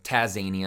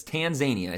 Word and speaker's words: Tanzania: I always want to like start saying Taz from Looney Tunes when I think of Tanzania. I don't know why. Tazania. I want to pronounce it that way, but it's Tanzania. I said it the first time.